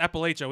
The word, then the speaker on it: Appalachia: Northern Georgia got the Appalachia starts there. Hold on. I said that wrong the other night. – Oh, you know, yeah, no, that. No, it's Appalachia.